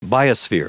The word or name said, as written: biosphere